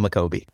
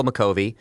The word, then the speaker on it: Makovi: How do you say Michael's last name? – It's Michael McCovey. – Michael McCovey.